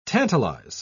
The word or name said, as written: tantalize